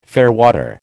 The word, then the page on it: fairwater: American English